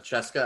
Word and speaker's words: CSKA: Kirill Kaprizov won 3 Gagarin Cups playing for Cheska, I think is how you actually pronounce it, but Cska in the KHL.